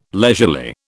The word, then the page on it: leisurely: British English